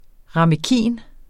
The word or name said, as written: ramekin